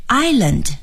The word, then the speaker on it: island: 纽约市的一部分呢是在岛上，那么岛用英语说是 island, I-S-L-A-N-D, island. Island，请朋友们一定要注意啊，这个发音应该是 I，然后呢 land island。